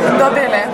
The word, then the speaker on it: Dovile: Dovile. – Dovile. Le, soft le.